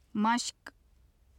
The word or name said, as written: mashq